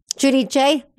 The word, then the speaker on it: Giudice: Giudice.